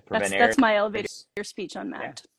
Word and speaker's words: Mappd: It's not map D, it's not MD app, it's just mapped. Just put an E in there and pronounce it like that. Yeah. We just couldn't afford the E. – There's like an invisible apostrophe, right? So it's MAPP invisible apostrophe D. Just say map.